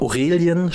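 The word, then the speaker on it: Aurélien: Ich hätte mich total gefreut, wenn ich glaube, Aurelien spricht man den Vornamen aus, Chuameni von Real Madrid zu den Bayern kommt.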